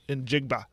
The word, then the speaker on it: Njigba: So, yeah, that's a good haul for them, especially with I can never say his name, Smith. – Njigba. – Thank you.